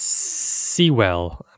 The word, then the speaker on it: Sewell: Rufus Sewell, I'm going to say, but there's no A. Rufus Sewell? – No, he's British.